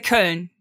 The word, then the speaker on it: Köln: Like, how would a German say, is it like, you know, is it Cologne or is it something? – Oh, in Germany we say Köln.